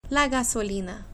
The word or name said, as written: la gasolina